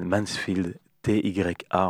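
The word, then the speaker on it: Mansfield.TYA: et donc voilà, c'est ça, c'est le groupe qu'elle a formé avec une autre personne qui s'appelle Mansfield, T-Y-A, on va le prononcer à la française, et le son s'appelle Cavalier.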